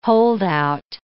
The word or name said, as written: holdout